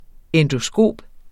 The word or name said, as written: endoskop